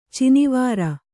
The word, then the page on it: ಚಿನಿವಾರ: Kannada